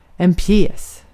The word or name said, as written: pjäs